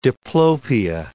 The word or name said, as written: diplopia